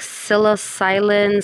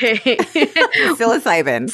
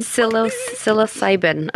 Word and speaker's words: psilocybin: It was something like psilocylin, psilocyphin, psilocybin. I can't pronounce it. – That's close enough, I think. Psilocybin. – Psilocybin.